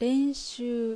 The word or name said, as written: Renshuu